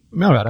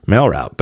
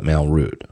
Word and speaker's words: MailRoute: How would you pronounce it? R-O-U-T-E. – MailRoute. I'd do the same thing. – MailRoute. But some people might call it MailRoute. Well, either way, M-A-I-L-R-O-U-T-E.